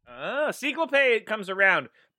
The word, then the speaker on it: Ciclope: Ciclope. – Ciclope.